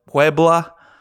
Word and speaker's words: Puebla: It celebrates the Mexican army's victory over France at the Battle of Puebla on May 5th, 1862. – That pronunciation, Puebla. Was on fleet. – Like Puebla.